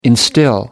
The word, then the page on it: instil: American English